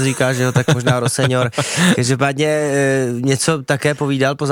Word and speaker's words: Rosenior: Přišel ze Štrasburku a asi byste si řekli, že podle toho, podle té výslovnosti seniora, anglicky senior, Rossinir, ale jeho otec se snad mluvil s někým z Premier League a vysvětlil to, že by se měl vyslovovat jako Liam Rosenior. – And just to clear it up, your surname is pronounced Senior. – My dad says Rosenior, yeah. Which is, it is the way to pronounce it, but as long as it's nice, you say something nice about me, I don't mind.